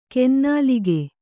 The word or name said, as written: ಕೊನೆನಾಲಿಗೆ